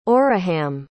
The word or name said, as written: orahamm